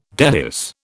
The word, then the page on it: dehisce: British English